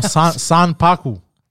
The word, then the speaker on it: Sanpaku: Sanpaki guys, what? – No, Sanpaku.